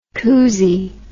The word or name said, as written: coosie